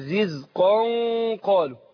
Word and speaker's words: رزقا: بس في حاجة نبينها وناخد بالنا الأخوان وأخواتنا المشاهدين والمشاهدات، بالنسبة لما أقول إيه كلما رزقوا منها من ثمرة رزقا قالوا، احنا عندنا تنوين بعد القاف رزقا، فبننطق نون في الاخر رغم ان هي منونه بالفتح، لكن بننطق في الاخر ايه نون. لو ما اقول رزقا نون اهي، جه بعدها القاف، يبقى هنا اخفاء، هنخفي نون النون الساكنه ديت اللي هي بننطقها مش بتتكتب، هنخفيها عند القاف، فاقول رزقا قالوا، رزقا قالوا.